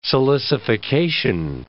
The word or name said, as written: silicification